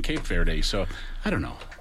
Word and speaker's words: Cabo Verde: Same thing with the team Cape Verde. – It's pronounced Cabo Verde, apparently.